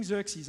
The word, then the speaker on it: Xerxes: But it's King Xerxes.